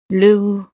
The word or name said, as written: le houx